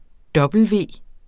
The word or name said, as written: W